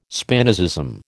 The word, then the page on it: Spinozism: American English